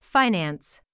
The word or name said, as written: finance